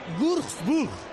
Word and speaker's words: Würzburg: Se escribe W-U, con diéresis esa U, R-Z-Burg. Una amiga mía que sabe mucho alemán siempre me corrige y me dice que es Würzburg. Pero claro, Würzburg.